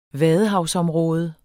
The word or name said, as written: vadehavsområde